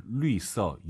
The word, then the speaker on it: 绿色: 아하, 绿色, 유땡땡. LU땡땡, 리어 할때그 발음이긴 한데 성조가 다르긴 하죠.